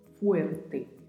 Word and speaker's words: fuerte: Na pronúncia se atente ao som da letra U também, na primeira sílaba. Fuerte. E essa letra R está localizada no meio da palavra e às vezes é difícil de fazer a pronúncia, então treine bastante. – Fuerte. E a pronúncia do T ao final, que assim como o T, quando pronunciamos lá na palavra puedes, também não tem o som de ti.